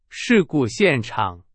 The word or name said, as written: 现场